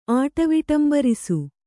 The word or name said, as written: ಆಟವಿಟಂಬರಿಸು